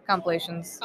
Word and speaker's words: compilations: I will watch like cringe complications with these. I just believe I said that wrong. – Compilations. Whatever.